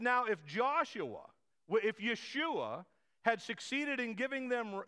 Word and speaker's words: Yeshua: They're the same. Yeshua. Joshua is how we pronounce essentially Yeshua in English. It's how we look at the Hebrew word and we bring it into English as Joshua.